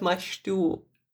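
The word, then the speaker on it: mangetout: And if you don't know what mashtu is, and it took me a while to remember how you pronounce it because it's from French and the spelling is quite weird.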